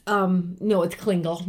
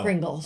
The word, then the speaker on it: Klingel: It's Klingel, not Kringle. – Okay. – So. – Okay. – And it's spelled E-L, not L-E, so there you go.